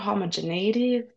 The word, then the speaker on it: homogeneity: But there's also a little bit of not homogeneity, that's the way you pronounce that word.